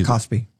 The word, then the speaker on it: KOSPI: The benchmark KOSPI, how do you say this? – KOSPI.